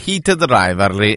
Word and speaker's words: Heated Rivalry: C'è Marco che scrive se si può ripetere il titolo, che non ha capito la pronuncia, è Heated Rivalry.